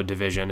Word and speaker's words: DVSN: For those who don't know Division, it's spelled DVSN.